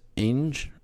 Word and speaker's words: Inge: First of all, your last name is I-N-G-E, and it's pronounced Inge? – Inge, yes. – Okay, good. So kind of like a hinge without the H on it.